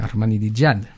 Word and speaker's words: Ahmadinejad: Y como Chávez, pues preconizando esto, pues también es aliado de Ahmadinejad, que uh-huh, lo digo bien, ¿no? – Sí, muy bien.